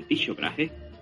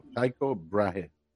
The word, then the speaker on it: Tycho Brahe: Tycho Brahe, publicó el conjunto de leyes que pasarían. Lo pronuncia mal, es Tycho, Tycho Brahe.